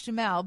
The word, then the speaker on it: Duhamel: I think it's pronounced Josh Duhamel, but you are absolutely right. I like the way you said it anyways. Carl usually... – Duhamel. I like that. – I know, he should change it. – It kind of rhymes with camel.